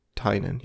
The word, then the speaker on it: Tynion: Wait, how did I pronounce that? – It's James Tynion, yeah.